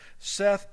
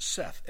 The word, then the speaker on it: saith: He says, he that hath an ear, let him hear what the Spirit saith unto the churches. Now the word S-A-I-T-H is not pronounced saith, it's pronounced Seth, S-E-T-H, like you'd say Seth.